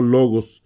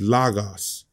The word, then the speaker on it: Logos: Muchos les enseñan a decir lagos. A mí no me gusta eso y no creo que es la pronunciación correcta, pero la realidad es nadie sabe. Pero como la mayoría del mundo de Europa tiene la misma forma de pronunciar las letras como en español, el O es O, ¿no? Entonces yo creo que es logos.